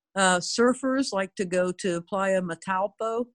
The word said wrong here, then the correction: Matapalo: and that's M-A-T-A, I mispronounced it, Playa Matapalo. – Matapalo. – That's Playa Beach, and that's spelled M-A-T-A-P-A-L-O.